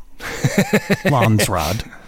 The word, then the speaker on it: Landsraad: Thank you for saying it correctly. Landsraad.